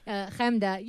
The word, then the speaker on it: Chemda: And Chemda, how do I say your name? I always get it wrong. – Nothing like that. – Yeah. – Chemda. You could say Hemda.